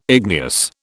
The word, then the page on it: igneous: British English